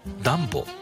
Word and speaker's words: DUMBO: ニューヨークを知っている方でも、ダンボという名前を聞いたとき、少し首をかしげることがあります。 DUMBO と書いてダンボと読みます。